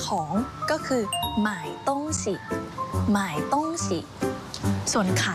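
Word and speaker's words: 卖: ส่วนคำว่าขายภาษาจีนนะคะจะออกเสียงคล้ายกับคำว่าซื้อหมายเลยแต่ว่ามันจะออกเสียงต่างกันนิดหน่อยขายภาษาจีนออกเสียงว่าหมาย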